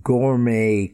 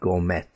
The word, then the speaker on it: gourmet: Now, just in case you didn't know this, words that have an origin in French and end with the letter T also have a silent T sound. For example, the ballet, not the ballet, the ballet. Gourmet cooking, not gourmet, gourmet cooking. Rapport, the rapport with his origins, the connection with his origin, rapport.